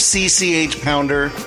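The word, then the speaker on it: CCH Pounder: It's spelled C-C-H. – I know how to spell it, but isn't it pronounced... – Seriously? You've never heard of C-C-H Pounder? That's how her name is pronounced. Spelled out. Not spoken phonetically.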